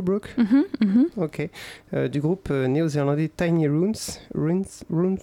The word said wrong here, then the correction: Ruins: Yeah, we don't know how to euh, pronounce uh, runes. On ne sait pas comment prononcer ruine. – Ruine, ruine. I think here it's a uh, ruin, ruine minuscule.